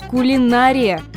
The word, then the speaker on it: кулинария: Так что давайте подведем итог. Кулинария и кулинария – абсолютно равноправные варианты.